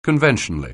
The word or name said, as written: conventionally